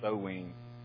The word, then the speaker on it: Samhain: It looks like Samhain to me, but it's pronounced Samhain.